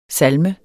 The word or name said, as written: salme